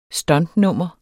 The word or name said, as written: stuntnummer